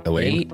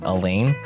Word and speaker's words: Alain: A-L-A-I-N. How do you say that? – Elaine. – A-L-A-I-N. Elaine.